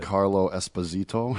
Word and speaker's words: Giancarlo Esposito: And they made a reference to Giancarlo. Is that how you say his name? I can't remember. I have to read it. – Giancarlo Esposito. – Yes.